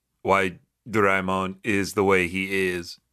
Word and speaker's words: Doraemon: Is it Doraemon or Doraemon? – Doraemon. – Ooh, okay. I gotcha. – That's how it's pronounced.